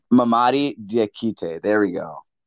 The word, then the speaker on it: Mamadi Diakite: You pronounced it right the first time. I think it's Mamadi Diakite. – Like, I think his first name rhymes with his last name.